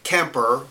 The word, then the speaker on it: Kemper: So I think that using that as an example, Tip Kemper, now first name's one syllable, second name two syllables.